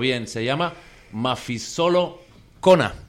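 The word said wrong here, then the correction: Mafikizolo: A ver, ¿puedes decirlo tú, que tal vez lo dices mejor? – Sí, Mafikisolo. – Mafikisolo. – Eso.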